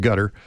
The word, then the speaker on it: Qatar: So from where I was at in Qatar, it's Q-A-T-A-R, but mm-hmm. it's pronounced gutter.